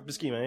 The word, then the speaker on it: Buscema: Buscema, yeah.